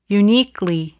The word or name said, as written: uniquely